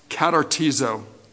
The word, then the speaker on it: katartizo: Perfect in the Greek is a word that's pronounced katartizo.